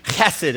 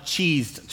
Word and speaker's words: Chesed: It's a Hebrew word, and it's actually Chesed is how you say it, but they spell that C-H-E-S-E-D. And when we started the church, we tried that spelling, but everybody started calling it Cheesed Church. And so I was like, can't have it. So we spelled it a different way, K-E-S-S-I-D.